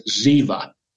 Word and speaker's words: Ziba: Ziba, and in Hebrew, the B has a V sound, so it's Ziba. That's how you would pronounce it. Ziba said to the king, "Behold, he remains in Jerusalem."